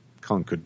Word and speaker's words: Concord: Because it's Massachusetts, it's pronounced Concord because they speak funny there.